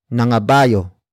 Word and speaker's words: nangabayo: Another example, nangabayo sila sa Baguio. Nangabayo sila sa Baguio. Nangabayo sila sa Baguio. It means they rode horses in Baguio. Word for word it's nangabayo sila sa Baguio.